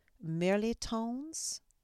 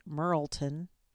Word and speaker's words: mirliton: So I picked another recipe, and forgive me if I butcher the way that this is pronounced, but it was called Glazed Mirlitons and Other Good Veggies. Now, a mirliton, I used to say Merleton which I found out was wrong, is a squash, and here in Southern California, call it chayote, C-H-A-Y-O-T-E, chayote squash.